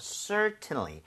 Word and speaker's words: certainly: 자, 그런데 얘는 강세가 앞에 E에 강하게 들어가요. – 네. – 그래서 어떤 경우에는 certainly 이렇게 깔끔하게 발음을 하는 사람도 있지만은 T를 거의 안 하는 사람도 있어요. 그럴 때는 certainly처럼 비슷하게 발음이 될 수도 있습니다.